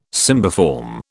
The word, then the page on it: cymbiform: British English